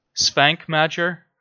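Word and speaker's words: Švankmajer: Jan Svankmajer. – Yeah. – Svankmajer.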